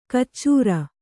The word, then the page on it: ಕಚ್ಚೂರ: kannada